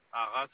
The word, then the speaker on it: Haras: Hara, ça s'écrit H-A-R-A-S.